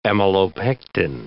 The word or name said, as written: amylopectin